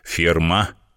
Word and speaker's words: Фирма: «Фирма!» — сделав ударение на последнем слоге, сказал мастер пошива, без сомнения гордясь своей работой.